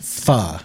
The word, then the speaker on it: pho: It's spelled pho, but it's pronounced pho. – I thought it was spelled P-H-U. – P-H-O with a little squiggly on top.